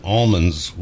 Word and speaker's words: almonds: Uh, by the way, uh, Christopher Di Matteo, our Los Angeles producer, says that Alicia Sylvester uses the Central California pronunciation almonds with the silent L.